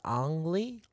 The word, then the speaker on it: Ang Lee: I remember that his name is pronounced Ang Lee.